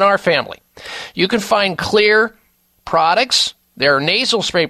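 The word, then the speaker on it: Xlear: You're looking for Clear, spelled with an X, X-L-E-A-R, Rescue Nasal Spray.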